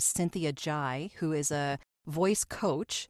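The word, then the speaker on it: Zhai: Cynthia Jai, but it's spelled Z-H-A-I, I believe.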